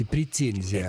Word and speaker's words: претензия: На первом месте мягкое произношение, на втором твердое. Но, тем не менее, возможно и претензия, и претензия.